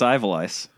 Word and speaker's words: Ivalice: It's Ivalice.